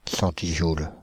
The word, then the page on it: centijoule: France (Île-de-France); /sɑ̃.ti.ʒul/